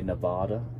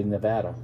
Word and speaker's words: Nevada: Most outsiders try to make it fancy, and they call it, they say Nevada. But it's really Nevada.